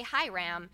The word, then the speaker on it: Hiram: Then king of Haram of Tyre, King Hiram, I have such a hard time pronouncing Old Testament names. Hiram, let's say Hiram, of Tyre sent messengers to David along with cedar timber and carpenters and stonemasons, and they built David a palace.